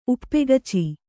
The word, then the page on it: ಉಪ್ಪಗಚಿ: uppagaci